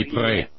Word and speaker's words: Ypres: Ypres.